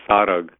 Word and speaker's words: Sarag: You know, so if you noticed when I was pronouncing, it's a little bit different than the most commonly pronounced way, which is Sarang. And in this Shabad, the title said Sarag. So sarag and sarang are same thing. It's a variation of a tippi. It's a nasalization of a consonant.